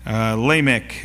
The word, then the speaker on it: Lamech: Lamech, it would be the English way of pronouncing it.